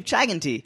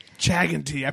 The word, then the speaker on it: Chaganti: Everyone tries to make my name sound like more Indian than it actually is. – Chaganti. – Like Anoop Chaganti. – Say it again? – It's just Anoop Chaganti.